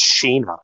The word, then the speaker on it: Sheena: Like in the manga, it's pronounced Sheena. It's Sheena.